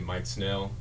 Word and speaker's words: Mike Snell: It's my name, Mike Snell. – Spell it for us. – M-I-K-E spaced S-N-E-L-L.